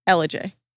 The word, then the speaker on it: Ellijay: L-I-J. – Okay. – Ellijay.